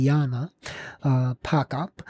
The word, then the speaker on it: Jana Pochop: I do see on her website that the H in her name is silent, and her last name is uh, P O C H O P. So if the H is silent, it would be Pockup. And her first name is, I suppose, it, she uses Jana. It's J A N A. It could be Jana, uh, Pockup.